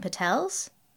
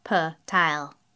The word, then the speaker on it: Patel: "Everyone knows about motels and Patels. Motel, Patel, they go together." He pronounces Patel the American way, Pat-el, instead of the Gujarati way, P-tile.